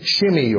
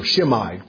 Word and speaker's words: Shimei: And he meets a man named Shimei, or Shimei, pronounce it either way.